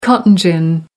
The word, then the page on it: cotton gin: American English